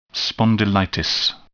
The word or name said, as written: spondylitis